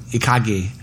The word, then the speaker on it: ikigai: And there's this model of happiness, which is what we're all trying to accomplish, out of Japan called Ikagi. I think I said it right, and it's pronounced or it's spelled I K I G A I.